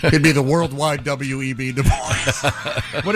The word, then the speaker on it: Du Bois: Du Bois. – No, it's pronounced Du Bois.